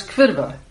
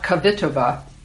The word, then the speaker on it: Kvitova: Pronounce Kvitova, three syllables, please, not Kvitova, and her unlikely, uncommonly quiet backstory.